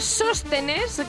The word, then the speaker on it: Sóstenes: Bueno, pues hoy es 10 de septiembre ya y felicitamos a todos los pulquerías, los sóstenes, que menos mal que llevan el acento en la O.